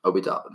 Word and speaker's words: Obi Toppin: Obi Toppin.